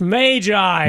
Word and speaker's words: magi: She would give you a hug, because it's not magi. It's magi. – Magi. – As she said, because it's the plural of magus, she says. – Yes. – Okay. So you've already scored points with Lynn because you pronounced it correctly.